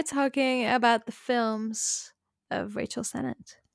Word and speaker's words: Sennett: I was saying Rachel Senna, but it's Rachel Sennett.